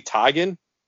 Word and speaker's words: Teigen: and I found out that apparently I've been saying her name wrong forever. Her name's actually pronounced Chrissy Teigen.